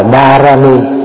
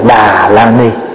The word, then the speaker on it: Đà La Ni: Đà La Ni là tiếng Phạn, nguyên gốc đọc là Đà Ra Ni. Đà Ra Ni mình âm lại là Đà Ra Ni hay là Đà La Ni.